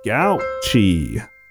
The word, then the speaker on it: Gauci: But first, here's the title of today's show, which is called Fringe Binge by Maddie Gauchy. I'm assuming that's how that's pronounced. – I think you nailed it.